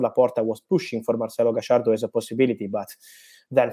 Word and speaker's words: Gallardo: Let me say my personal opinion, my friend, is about Gallardo. I try with Argentinian pronunciation of his name because I love this manager.